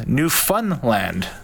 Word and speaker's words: Newfoundland: Newfie Mick has texted in, says that the pronunciation is Newfunland. – That's what I got. F-U-N. I got a message from somebody on Twitter, Newfoundland. – So like he's putting the fun in Newfoundland.